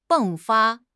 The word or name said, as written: 迸发